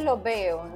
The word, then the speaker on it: los veo: So the way that this is pronounced, because if you were reading it, it's like los veo. So literally, I will see you all, right? You're talking to a group like los. – Sí. – But in Puerto Rico, sometimes the last S isn't pronounced really strong, is it? – Exacto.